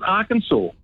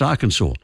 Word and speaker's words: Arkansas: So Kansas is pronounced Kansas, but the one next door, Arkansas, is pronounced Arkansas. They both come from the same Native American word, what we used to call Indian words.